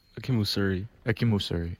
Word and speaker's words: Akinmusire: Akinmusire.